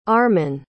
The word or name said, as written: armen